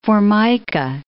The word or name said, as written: Formica